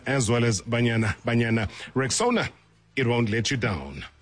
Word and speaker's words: Rexona: It is pronounced Rexona, simple, strong, and easy to remember.